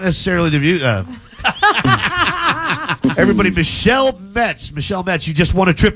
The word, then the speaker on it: Metz: Michelle Ment. – Spell that last name? – M-E-T-Z.